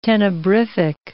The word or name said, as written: tenebrific